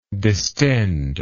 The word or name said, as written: distender